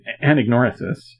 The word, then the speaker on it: anagnorisis: But he defined anagnorisis,